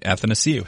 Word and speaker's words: Athanasiou: because he reminded me it's pronounced happy to see you. – That's right. Athanasiou.